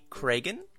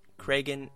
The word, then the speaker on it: Kragen: As for me, you can find me on Twitter at Bradley Cragen. Cragen is spelled K-R-A-G-E-N.